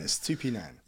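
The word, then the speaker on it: Estupiñán: Estupinan. – Yeah. You got to say the full. – Yeah. Are we pronouncing it right? Yeah.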